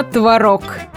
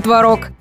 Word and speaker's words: творог: Да-да, очень важно, чтобы в жизни каждого из нас был свежий и вкусный творог. И никак не творог. Тем более, ставить ударение на последний слог стало нормой еще в 19 веке.